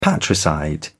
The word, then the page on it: patricide: British English